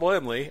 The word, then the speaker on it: Laemmle: And I'm going to keep saying his name now that I know how to pronounce it. Okay, ladies and gentlemen, his name is literally spelled L-A-E-M-M-L-E. I'm so glad that Steve is here to tell me how to pronounce that.